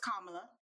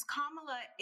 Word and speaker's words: Kamala: So it's Kamala, not Kamala.